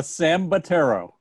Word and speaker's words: Sambataro: It's Sambataro.